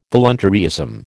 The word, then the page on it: voluntaryism: American English